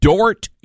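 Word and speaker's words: Dordt: There is a Christian university, Dort University, that's D-O-R-D-T, the D is silent,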